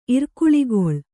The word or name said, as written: ಇರ್ಕುಳಿಗೊಳ್